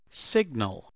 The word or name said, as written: signal